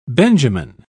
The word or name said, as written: benjamin